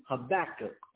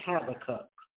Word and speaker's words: Habakkuk: The second one is in Habakkuk, or some people may pronounce it Habakkuk.